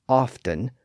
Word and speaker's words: often: By the 1700s, most sources suggest that the T was completely silent. But in the last century or so, it started to reappear in the pronunciation. So today, you may hear the word pronounced as often, presumably because there's still a T in the spelling. But again, that T had largely become silent until the past century or so.